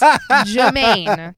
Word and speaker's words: Jermaine: There is no R. It's Jermaine.